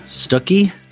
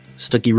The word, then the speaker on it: Stuckey: I'm here with my guest, Nate Stuckey. Stuckey rhymes with cookie.